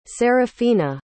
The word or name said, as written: sarafina